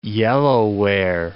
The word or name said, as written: yellowware